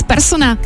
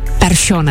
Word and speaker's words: Persson: Z Larsona je najednou Larsson a z Persona Persson.